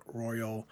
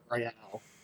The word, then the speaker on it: Royal: All right, so we get into the second half, and in the 62nd minute, we get a red card on Emerson Royal. What are your thoughts on this card? – Well, two things. Number one, because nothing can be pronounced the way you think, it's Royale.